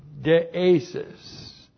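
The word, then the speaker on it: deesis (δέησις): And then we have deesis. Deesis looks like that in the Greek, D-E, long E-S-I-S, deesis.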